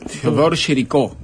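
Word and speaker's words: Théodore Géricault: Theodore Géricault, creo que es el nombre, pero yo lo debo pronunciar como... – Está, perfecto.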